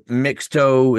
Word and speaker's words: Mixto: Mito or Mixto is how it would be spelled, M-I-X-T-O. That's how you pronounce it.